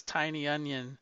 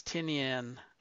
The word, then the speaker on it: Tynion: I wonder if if I'm ever pronouncing it right, because he always says Tiny Onion, so like it's Tynion.